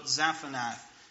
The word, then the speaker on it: Zaphnath: So Zaphnath, right? It is likely this Egyptian phrase, which is pronounced roughly Zatanath.